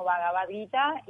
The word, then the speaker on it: Bhagavad Gita: Pero um, se pronuncia como Vagabadita y se traduce como la canción de Vagabán.